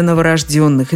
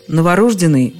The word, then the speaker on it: новорождённый: Произносить это слово так и только так настойчиво рекомендуют словари. Вариант «новорожденный» в них сопровождается строгой пометой «не рекомендуется», но, ну, значит, действительно нельзя, форма просторечная. Собственно, это относится и к слову «законно рожденный», а также «незаконно рожденный».